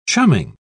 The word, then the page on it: chumming: British English